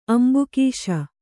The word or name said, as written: ಅಂಬುಕೀಶ